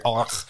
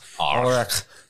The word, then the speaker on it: Ulrich: he pronounces Ulrich so weird. Ulrich. – Ulrich. No, he does it right. That's the German pronunciation.